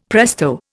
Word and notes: presto: Indian

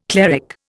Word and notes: cleric: Indian

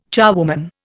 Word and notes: charwoman: Indian

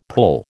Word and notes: pull: American English

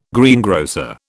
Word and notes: greengrocer: British English

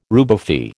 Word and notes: rubefy: American English

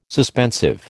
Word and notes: suspensive: American English

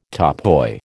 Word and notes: topoi: American English

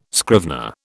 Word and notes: scrivener: British English

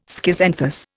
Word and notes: schizanthus: Indian

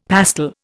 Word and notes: pastel: Indian

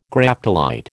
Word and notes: Graptolite: American English

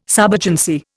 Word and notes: subagency: Indian

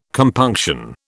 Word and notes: compunction: British English

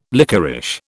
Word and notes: lickerish: British English

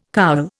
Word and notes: cowl: Indian

